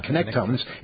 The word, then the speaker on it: connectome: It looks like connect to me, but it's Connectome, I think is how you're supposed to pronounce it. You ever said that word? – Connectome? – It's spelled connect to me, but Connectome.